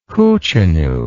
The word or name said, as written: hootchinoo